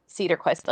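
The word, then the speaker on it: Cederquist: I looked it up and it was just Cedarquist. Like, it was very straightforward.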